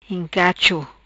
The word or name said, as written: Incacho